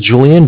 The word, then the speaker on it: Julian: And it's my name is Julian, J-U-L-I-A-N.